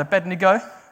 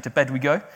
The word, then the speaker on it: Abednego: In the same book, Shadrach, Meshach, and Abednego, glad I said his name right, I often say to bed we go, and he, they were all thrown in a fiery furnace.